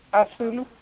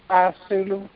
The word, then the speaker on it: Asulu: Asulu. – I'm sorry? – Asulu. – Okay, can you spell that? – It's A-S-U-L-U.